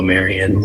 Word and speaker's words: Marion: Mr. Chairman, members of the committee, for the record, my name is Noah Marion. Last name is spelled M-A-R-I-O-N.